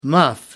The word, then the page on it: muff: American English